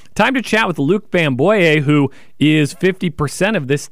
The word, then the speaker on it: Bamboye: Luke, and then it's Bamboye. – Bamboye. – Yeah.